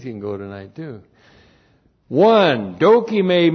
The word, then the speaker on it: dokime: D-O-K-I-M, long E, dokime.